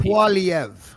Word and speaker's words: Poilievre: Uh, but Poilievre, uh, how do you say his name, by the way? I call him Polyev. – Polyev. – Poly, but uh, the English tongue is Polyev.